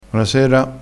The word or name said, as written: bonasera